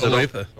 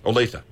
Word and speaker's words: Olathe: So according to the Consumer Affairs report here, the best drivers in the country, Salinas, California, with Olathe, Kansas. Didn't you used to live there? – Yes, I did. I did. – Is it, did I pronounce it correctly? Is Olathe. – Olathe. Yep. Okay.